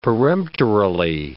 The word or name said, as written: peremptorily